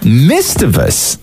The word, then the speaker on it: mischievous: Mischievous. Yep. There's no T. I see no T in there.